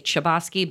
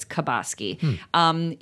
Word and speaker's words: Chbosky: If you guys heard that, I was doing how to pronounce because sometimes I say Chbosky, but it's Chbosky.